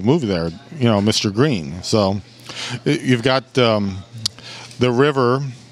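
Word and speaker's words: Gruene: It's spelled Gruen. Like the German spelling, but Bruin. They pronounce it Bruin. Green. – Green.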